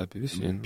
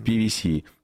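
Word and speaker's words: PwC: Тут аудиторская компания, PVC, по-моему, правильно читается. – PricewaterhouseCoopers, да, PVC. – PVC. – ПВК по-русски.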